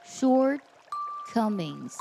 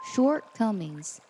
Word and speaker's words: shortcomings: 在朗读英文版的时候呢，有个单词需要特别注意，它指的是缺点的意思，它读作 shortcomings shortcomings，这里用的是个复数，读的时候呢，这个 t 不能读出来，只能做口型不发声， shortcomings shortcomings，这样读起来才会非常的自然和流畅。